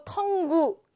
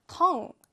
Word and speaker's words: tongue: 여러분 이걸 보고 tongue 이렇게 발음하시면 안 됩니다. tongue.